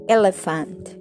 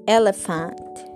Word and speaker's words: elephant: Eh, elefante, elephant, elephant. Es casi como una E. La primera es casi que, no la pronuncias mucho, ¿ok? Elephant.